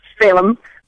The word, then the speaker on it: Phelim: We're talking to Anne McElhinney, and she and her husband, Phelim, that's pronounced Phelim, correct? – Phelim.